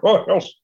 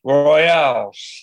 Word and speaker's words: Royales: I said, it sounds like a baseball team to me, the Five Royals. But we never heard that before. Our disc jockey in Memphis called them the Five Royales. Mm-hmm. It's spelled the same, but it's bigger. Royales.